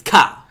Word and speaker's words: Kyle: He doesn't like when people call him Kyle. It's one syllable.